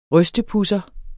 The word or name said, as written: rystepudser